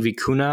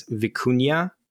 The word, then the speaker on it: vicuña: So we've been saying vicuna. I think it's vicuña.